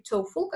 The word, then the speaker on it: TOEFL: Кстати, хочу обратить внимание, да, по-разному произносят коллеги этот экзамен, это все-таки TOEFL, как пальчик ноги, да, TOE, поэтому вот это правильное произношение этого слова.